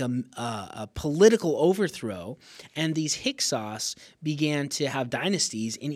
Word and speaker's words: Hyksos: It's spelled H-Y-K-S-O-S, Hyksos.